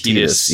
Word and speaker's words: Tidus: I believe the official pronunciation of the main character's name is Tidus, even though I think that sounds silly and I will never say it.